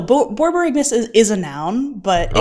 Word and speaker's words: borborygmus: Borborygus? – Borborygmus. B O R. – Miss. Okay. – B O R Y G M U S.